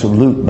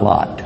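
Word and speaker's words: Lut (لوط): When the people that followed Lut, his name's pronounced Lut, by the way, not Lot. That's English. His name is Lut.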